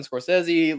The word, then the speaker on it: Scorsese: Did it just go right into the pockets of Martin Scorsese? Why did I say his name like that? Martin Scorsese, Leonardo DiCaprio, Robert De Niro.